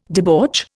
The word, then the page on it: debauch: Indian